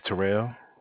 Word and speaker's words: Terrell: Oh, my name is Terrell.